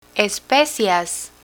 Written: especias

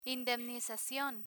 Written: indemnizacion

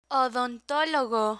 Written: odontologo

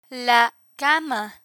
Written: la cama

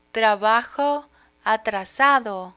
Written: trabajo atrasado

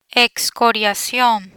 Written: excoriacion

